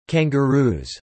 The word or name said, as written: Kangaroos